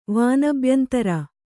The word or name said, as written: ವಾನಬ್ಯಂತರ